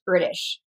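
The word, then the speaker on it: Erdős: It's actually pronounced Erdos.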